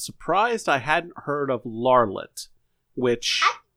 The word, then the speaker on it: Larlitt: I'm surprised you've never heard of Larlet. – Larlet. – And it's spelled L-A-R-L-I-T-T, and you can roll a history check for me, as that is all Thunder Wreck will say.